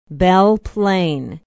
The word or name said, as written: belle plaine